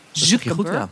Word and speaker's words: Zuckerberg: En nu is dat Elon Musk en Mark Zuckerberg. Zeg ik het goed zo? – Zuckerberg.